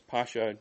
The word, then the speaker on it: Pascha: Many Pascha or Pasach, it's Pascha normally is the way it's pronounced,